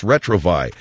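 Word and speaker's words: Retrouvaille: R-E-T-R-O-U. R-E-T-R-O-U-V-A-I-L-L-E. It's pronounced Retrovi.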